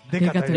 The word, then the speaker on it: 刑事: 解刑事かね刑事と書いてでかと読むねビッグマン本